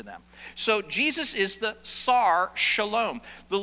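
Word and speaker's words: Sar, shalom (שר שלום): It is pronounced sar, with that little hyphen, shalom. Sar, shalom.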